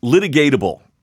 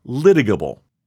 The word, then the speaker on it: litigable: I had trouble pronouncing the word litigatable, which is not how you pronounce it. That's how I was pronouncing it. And my wife has been listening in, reading in, and that's not what the word says. I had to Google it and type in litigable pronunciation. And that's how I know how to pronounce litigable.